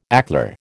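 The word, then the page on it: éclair: American English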